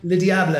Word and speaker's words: Le Diable: Absolutely, and I also agree that with the ending, the thing about this song, the part one, uh, how do you pronounce it? Le Diable. – Le Diable. It's French for the devil.